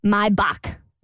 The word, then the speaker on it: Maybach: Apparently, we've all been pronouncing Maybach wrong. – Yeah. – I just Googled it. It's a German word. And apparently you're supposed to pronounce it Maybach. – My back.